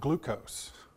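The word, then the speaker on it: glucose (γλεῦκος): The sweet wine is actually a different word, and that word is actually pronounced glucose. Does that sound familiar?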